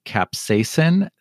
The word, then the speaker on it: capsaicin: And I have to just explain, he mispronounced the word. It's actually capsaicin.